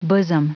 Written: bosom

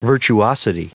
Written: virtuosity

